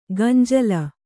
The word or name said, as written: ಗಂಜಳ